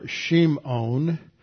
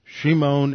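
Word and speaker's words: Shimon (שמעון): In the Hebrew, it's Shimeon. Are Shimon and Levi. Shimon and Levi. Hebrew tends to put the accent on the last syllable.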